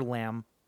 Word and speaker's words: Lambert: And he was nice enough to tell us that it's like Christopher Lambert.